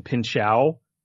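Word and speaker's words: Pinchot: I believe it's Bronson Pinchow is how it's, like, supposed to be pronounced.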